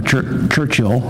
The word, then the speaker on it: Churchill: Churchill, I said it right this time, is backburned.